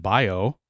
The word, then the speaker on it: Baio: She went over to Stephen's house, where she found Stephen's 2011 Chrysler and Stephen's new girlfriend, Michelle Busman Bayo, like Scott Bayo, Busman Bayo. It's spelled like Scott Baio. Busman Baio.